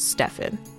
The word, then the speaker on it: Steffen: Because my brother's name is Stefan, but they spell it S-T-E-F-F-E-N. So it's Stefan.